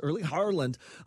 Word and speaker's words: Erling Haaland: Erling Haaland. I'll say it like the Scottish people do on the broadcast. Erling Haaland, obviously the massive superstar for Norway.